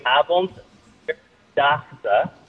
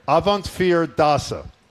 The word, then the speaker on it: Avondvierdaagse: So it is, I can say slowly, Avondvierdaagse. – Avant, what's the middle? – Avondvier, the number 4, fear. – Avondvierdaagse.